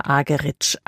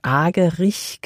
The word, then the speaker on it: Argerich: Zum Orchester mit Charles Dutoit kommt jetzt Marta Argerich als Solistin dazu. Hierzulande wird sie oft eingedeutscht Argerich genannt, aber sie selbst spricht sich Argerich.